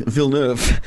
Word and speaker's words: Villeneuve: I'm going with as in Jacques Villeneuve.